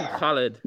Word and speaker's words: Khalid: So speaking of Arabic words, can you give me any words where I can use a good huh sound? Because most Americans can't pronounce it, so I like to show off that I can. – say the word, like just the name Khalid.